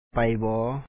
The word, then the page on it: ໄປບໍ: pay bɔɔ